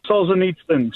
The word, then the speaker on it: Solzhenitsyn: Solzhenitsyn? Is that correct? – Solzhenitsyn. – Solzhenitsyn. All right.